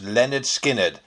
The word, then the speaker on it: Lynyrd Skynyrd: In August of this year, Lynyrd Skynyrd released their debut album, pronounced Lynyrd Skynyrd.